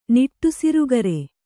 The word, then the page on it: ನಿಟ್ಟುಸಿರುಗರೆ: kannada